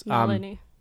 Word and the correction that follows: Nalini: What? – Nalini.